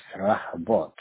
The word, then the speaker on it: Rehoboth: And then the business name is going to be, I'll spell it for you, R-E-H-O-B-O-T-H, right? – Yes, Rahabot.